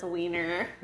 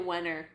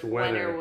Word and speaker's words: Wehner: It's spelled W-E-H-N-E-R. – Let me turn the phone so I can read it. That says Wiener. – No. – No, it's probably Wenner. – I would say it's Wenner.